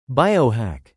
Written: biohack

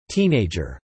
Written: teenager

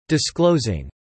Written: disclosing

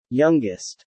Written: youngest

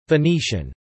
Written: Venetian

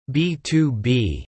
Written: B2B

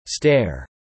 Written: stair

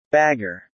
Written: bagger

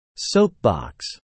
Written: soapbox

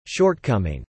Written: shortcoming

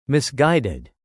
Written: misguided

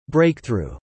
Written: breakthrough

